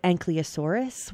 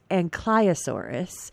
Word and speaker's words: Ankylosaurus: I always called it Ankylosaurus when I was young, but I think it's now the more proper pronunciation is Ankylosaurus.